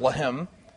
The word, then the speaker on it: Lehem: And so if it's pronounced Lehem, it's bread.